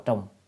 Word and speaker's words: trùng: Chữ này đọc là trùng.